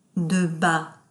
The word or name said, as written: de bas